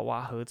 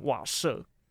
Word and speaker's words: 瓦合: 不过呢，那时候闹了一个小笑话，就是在这边跟大家道个歉，就是那个时候我在介绍《瓦合》这一张专辑的时候，不知道为什么我一直就是看错那个字，然后我把“ 瓦合” 这两个字把它念成“ 瓦舍”，然后而且一直都没有发现，是在我回家的时候看网络上的论坛啊，有人在讨论说有没有人跟他一样把“ 瓦合” 这两个字一直以为是“ 瓦舍” 呢？